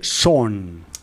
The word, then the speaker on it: Sean: como dicen en la serie, Son. Son, sí. Porque se escribe sean, pero ya sabéis que.